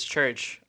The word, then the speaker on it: Churkh: So social media name is Church, but it's spelled C-H-U-R-K-H.